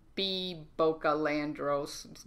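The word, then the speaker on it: Bea Boccalandro: And you can just find me B Boca Landros.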